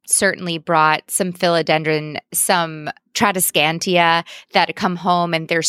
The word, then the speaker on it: Tradescantia: You said it right the first time. Yeah, Tradescantia.